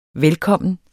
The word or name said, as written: velkommen